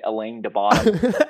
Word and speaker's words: Alain de Botton: I always say it, Alain de Botton.